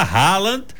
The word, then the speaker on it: Haaland: É a pronúncia do nome do cara mesmo. A gente fala Haaland. Mas a pronúncia em norueguês é assim.